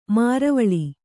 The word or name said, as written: ಮರವಾಳ್